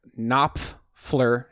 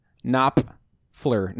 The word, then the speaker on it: Knopfler: I made sure that's how you say it. It's got a P and an F. Knopfler. Knopfler. You can't say it fast.